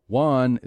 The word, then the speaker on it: Juan: Juan is definitely pronounced the same as one.